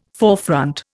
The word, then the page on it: forefront: Indian